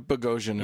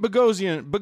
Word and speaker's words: Boghossian: We have Pete Boghossian. Sorry, Pete. He all good. – He explained to us earlier, listeners, that it rhymes with explosion.